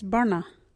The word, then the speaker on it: barna: Our local Wadjuri word is burna. What is burna? Burna is a ground, dirt or country. You can use burna in this sentence. That burna, hard and stony. Burna is spelt B-A-R-N-A.